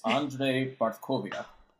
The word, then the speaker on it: Andrzej Bartkowiak: How do you actually pronounce it? – Well, first of all, I apologize to my sister-in-law for already forgetting her lessons on how to say this name. It's Andrzej Bartkowiak. – Oh, dang it. All right. – And the thing that I have a problem with is that you're supposed to roll the R in like Bart.